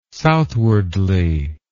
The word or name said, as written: southwardly